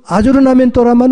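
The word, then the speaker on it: aggiornamento: 그 아조르나멘토라는 단어가 외국말을 번역을 할 수가 없으니까 전 세계 모든 나라에서 아조르나멘토라는 말을 씁니다.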